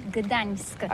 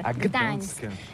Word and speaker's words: Gdańsk: Ça s'écrit G-D-A-N-S-K. On dit Gdansk ou Gdansk, on prononce le G ou pas? – Oui, c'est pas très facile pour des étrangers, mais remarque bien que la langue polonaise, c'est la langue où on prononce chaque lettre qu'on écrit. C'est pourquoi on dit Gdańsk.